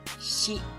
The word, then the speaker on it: shi: Notice that it's pronounced as shi and not si.